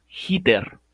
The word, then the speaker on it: Heater: canción se llama Hitter, se escribe Heater.